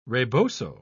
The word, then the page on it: rebozo: British English